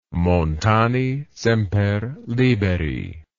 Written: montani semper liberi

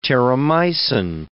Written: Terramycin

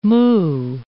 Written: moo

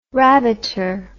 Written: rabbiter

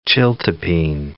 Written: chiltepin

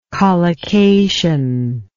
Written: collocative

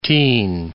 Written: tyin

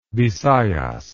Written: bisayas

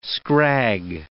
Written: scrag

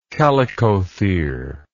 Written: chalicothere

